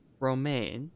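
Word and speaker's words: Romijn: So to start, Rebecca Romaine,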